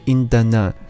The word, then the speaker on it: 阴达呢: 阴达呢，是朱婧汐写给奶奶的歌，歌名是音译，准确的发音就是阴达呢，这是朱婧汐的爷爷小时候在庙里面当和尚用的傣文名字，而这个名字呢，很长时间里面只有奶奶一个人知道。